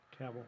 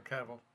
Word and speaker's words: Cavill: Yeah, so, Henry Cavill. Cavill. How do you say his name? – Cavill. – Cavill, yeah.